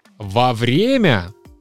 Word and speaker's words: во время: Второй пример это во время. Пишется раздельно и ударение падает на Е во время.